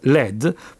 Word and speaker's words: Lead: Page riprese il nome ma cambiò Lead, che si pronuncia comunque Led, proprio con Led, L-E-D, per evitare problemi di pronuncia, perché Lead, così come è scritto, può essere anche letto Lead, e vuol dire, insomma, comando, guidare.